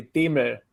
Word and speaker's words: Demel: It's gotten butchered, but yeah, the original pronunciation is actually Demel, which is impossible to say in English.